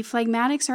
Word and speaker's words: phlegmatic: It's spelled P-H-L-E-G-M-A-T-I-C. It's pronounced phlegmatic.